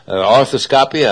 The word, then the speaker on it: arthroscopy: Arthroscopy, I pronounced that right there.